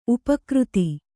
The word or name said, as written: ಉಪಾಕೃತ